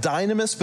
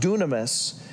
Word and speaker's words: dunamis: In the Greek word, it looks like dynamis, but it's pronounced dunamis.